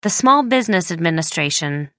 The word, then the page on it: small business administration: American English